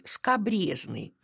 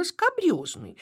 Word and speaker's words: скабрёзный: Ну, нередко можно услышать скобрезный вместо правильного «скабрёзный».